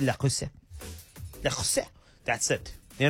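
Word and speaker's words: Le Creuset: Dua Lipa and Callum Turner gave their guests a 12-piece lacrosse. You understand, I'm not even saying it right. They gave La Crusade.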